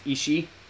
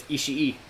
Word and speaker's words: Ishii: I thought it was just pronounced Ishii. – Yeah. – But if there's that extra I, you have to pronounce it. – Yeah. – So it's Ishii.